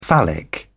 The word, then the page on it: phallic: British English